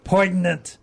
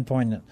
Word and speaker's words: poignant: Oh, I should pronounce the G because it drives people crazy if I pronounce the G. So, poignant. Instead of saying poignant.